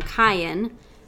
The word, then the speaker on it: Kyan: And then, I'm going to pronounce it probably wrong, Kyan, like Ryan.